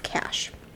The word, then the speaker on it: Kach: Her last name is spelled K-A-C-H, but it's pronounced Cash.